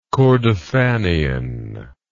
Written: kordofanian

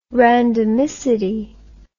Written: randomicity

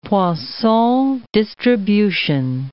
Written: Poisson distribution

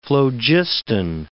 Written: phlogiston

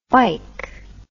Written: fike

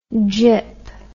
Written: jip

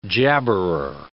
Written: jabberer

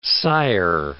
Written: sigher

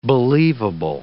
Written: believable

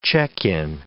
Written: check-in

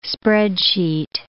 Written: spreadsheet

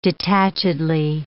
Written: detachedly

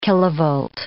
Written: kilovolt

